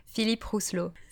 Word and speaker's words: Philippe Rousselot: So, a bit of French pride here. So his name is Philippe Rousselot.